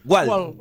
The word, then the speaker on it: Wald: Mirá, decime cómo se dice en alemán, Wald. – Wald. – Wald. Wald. – Como se escribe, se pronuncia. – Wald. – Sí. – Wald. – No tiene diéresis.